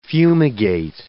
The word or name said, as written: fumigate